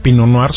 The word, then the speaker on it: Pinot Noir: Se escribe Pinot Noir, se pronuncia Pinot Noir.